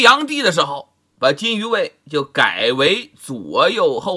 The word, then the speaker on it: 金吾卫: 左右后卫啊，就是金鱼卫。金鱼卫很多人呢都读成金吾卫，其实应该叫金鱼卫，负责皇帝出行的时候前驱后殿，日夜巡查，止宿实施警戒之责呀。